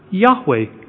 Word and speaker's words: Yahweh: Y-H-W-H. And we pronounce that word Yahweh.